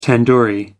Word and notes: tandoori: American English

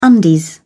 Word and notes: undies: British English

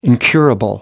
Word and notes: incurable: American English

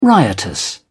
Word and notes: riotous: British English

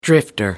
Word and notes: drifter: American English

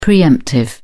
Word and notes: pre-emptive: British English

